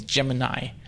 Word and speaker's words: Gemini: Although I think the correct pronunciation pronunciation is Gemini.